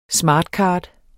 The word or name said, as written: smart